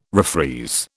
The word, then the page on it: refreeze: British English